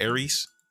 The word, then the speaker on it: Ares: Is that how we pronounce that? Ares? – Yes. – Okay, like the Greek.